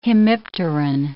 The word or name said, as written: hemipteran